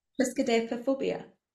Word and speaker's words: Triskaidekaphobia: Triskaidekaphobia.